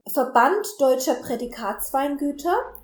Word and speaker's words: Verband Deutscher Prädikatsweingüter: How do you pronounce the VDP? – Verband Deutscher Prädikatsweingüter. – Perfect. That's the VDP.